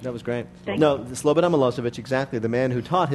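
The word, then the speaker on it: Slobodan Milošević: I can't believe you're going to make me pronounce this, but it's Slobodan Milosevic.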